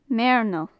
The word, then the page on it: meirneal: Gaelic